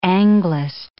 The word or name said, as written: Anglist